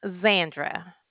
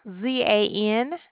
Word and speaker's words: Zandra: Zandra. – Could you spell it? – Z as in zebra, A-N-D-R-A. – Z-A. I'm sorry, say it again. – Uh-huh. Zandra, just like Sandra, but with a Z. – Zandra. D-R-A?